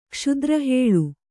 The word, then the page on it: ಕ್ಷುದ್ರಹೇಳು: Kannada